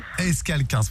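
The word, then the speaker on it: Escalquens: comment on prononce le nom de votre ville? Parce que je ne veux pas l'écorcher. – Escalquins. – J'aurais dit Escalquins, moi.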